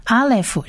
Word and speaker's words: Aleph: 我们先来看第一列的第一个字，第一个字只有两个字母是 Aleph 的第一个字母是 Aleph，相信听众朋友已经很熟悉 Aleph 是末音字母，它不发音，它只发它下面的母音短 A 的音，这个看起来像 1234 的 E，这个发短 A，所以第一个音就是 R 的音。